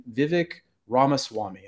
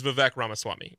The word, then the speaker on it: Vivek Ramaswamy: And it's about this candidate named Vivek Ramaswamy, and I don't know if I've pronounced that exactly the right way, and I apologize if I've.